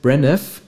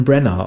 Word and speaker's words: Branagh: ich sage mal Kenneth Branagh, aber angeblich spricht man einen Brenner aus, dann möchte ich es hiermit korrigiert.